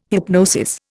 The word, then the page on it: hypnosis: Indian